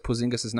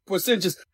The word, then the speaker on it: Porzingis: Smith mispronouncing Kristaps Porzingis' name. – Porzingis.